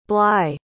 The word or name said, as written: bly